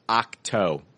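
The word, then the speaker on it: Ahch-To: The trailer then moves to Octo, it's pronounced Octo. I've always been saying Octo. It's pronounced Octo.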